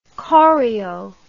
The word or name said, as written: choreo